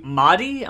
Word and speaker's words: Mahdi: The Mahdi, am I pronouncing that right? – I think so, yeah. – M-A-H-D-I.